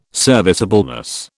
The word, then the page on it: serviceableness: British English